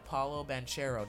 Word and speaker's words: Banchero: very clear that Paolo Banchero, did I say that right? – Yes, Paolo.